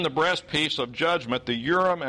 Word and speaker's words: Urim: That's how I've usually heard these words pronounced in English. Hebrew has a tendency to want to stress the final syllable. So in Hebrew, this would really be the Urim and the Thummim.